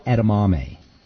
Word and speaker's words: edamame: edamame. Edamame is how you say it.